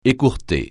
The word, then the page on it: écourter: francais